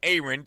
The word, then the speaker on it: Aaron: It's spelled like Aaron, A-A-R-O-N, pronounced Aaron.